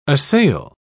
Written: assail

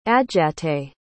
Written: adjatay

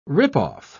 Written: rip-off

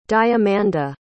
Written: diamanda

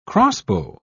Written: crossbow

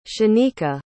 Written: shaneka

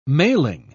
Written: mailing

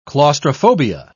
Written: claustrophobia

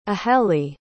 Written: ahelie

